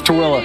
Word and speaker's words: Tooele: This is Tooele. – Tooele. – Remember it like this. Tooele, you go to the store with me later? – You know what?